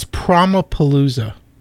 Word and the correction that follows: Promopalooza: It's Promopalooza. Promotion.